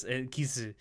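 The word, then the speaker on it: kisu (キス): Well, actually, they're exact homonyms in Japanese because that's how they pronounce kiss. – That's true. That's how they pronounce the word kiss, kisu.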